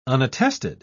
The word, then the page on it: unattested: British English